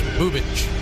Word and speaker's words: Bubic: Spelled B-U-B-I-C, pronounced Chris Bubich.